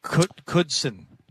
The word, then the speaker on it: Knudsen: Our engineer is Dennis Knudsen, but it's just pronounced Knudsen. The Knudsen. N is silent.